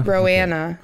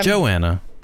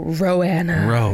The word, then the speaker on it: Roanna: How do you spell that? R-O-A-N-N-A? Rowanna? – Yeah, Rowanna. – Okay. All right. – Yeah. I don't know. It's weird. – Fine. – And so anyway. – Not Joanna. – No, Rowanna.